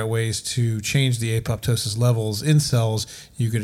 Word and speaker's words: apoptosis: Apoptosis. What do you know about it? – Apoptosis. – Is that how it's pronounced? – Yeah.